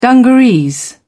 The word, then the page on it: dungarees: British English